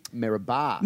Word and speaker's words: Meribah: I'm from a town called Peebinga and right nearby is another equally small town called Maribor. – Oh, really? – Meribah. – Meribah? – Spelled M-E-R-I-B-A-H. – Yeah, that's how we spell it.